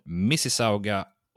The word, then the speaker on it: Mississauga: Eh, och Billy Talent är ett kanadensiskt punkrockband från Mississauga, Ontario. Vilket fantastiskt bra uttal.